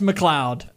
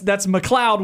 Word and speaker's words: MacLeod: M-A-C-L-E-O-D. – I think that's McLeod. – Okay, that's a weird way to pronounce it. – It is a weird way to spell it, yeah. But that's McLeod with an A.